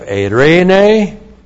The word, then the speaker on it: eirene: And then we have eirene, looks like that in the Greek, E-I-R, long E-N, long E. Eirene. We get our name Irene from eirene. Eirene.